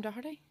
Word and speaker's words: Doherty: It's weird that you pronounce my name Doherty. – You always tell me that, but I always pronounce it Doherty. – That is the correct way to do it. – It's because I don't have a Derry accent.